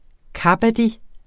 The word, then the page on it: kabaddi: ˈkɑbadi; kaˈbɑːdi